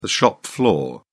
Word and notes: shop floor: British English

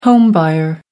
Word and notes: home buyer: American English

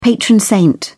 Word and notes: patron saint: British English

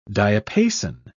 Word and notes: diapason: British English

